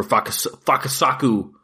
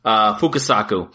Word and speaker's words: Fukasaku: Next up was a 1968 film directed by Kenji Fukasaki, or Fukasaku, I think is how you say it. Fukasaku.